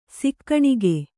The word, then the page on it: ಸಾಕಾಣಿಕೆ: sākāṇike